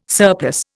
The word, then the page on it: surplus: Indian